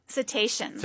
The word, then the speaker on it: cetaceans: But she's also recently been to Portugal to study, is it cetaceans? How do you say it? – Cetaceans.